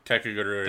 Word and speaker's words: Kakegurui: I'm watching that, and is it pronounced Kakagiri, the gambling show? – Kakagiri. – Thank you.